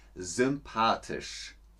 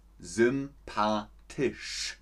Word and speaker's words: sympathisch: Ich finde dich sehr sympathisch. Sympathisch. Das spricht man richtig voll aus wie Schlange. Oder wenn ihr den Wasserhahn aufmacht. So spricht man das aus.